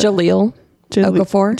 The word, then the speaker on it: Jahlil Okafor: Jahlil Okafor.